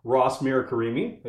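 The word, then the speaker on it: Ross Mirkarimi: And today we are incredibly lucky and grateful to have the current sheriff and maybe soon to be reelected sheriff, Ross Mirakarimi.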